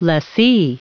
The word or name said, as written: lessee